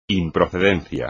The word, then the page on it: improcedencia: espagnol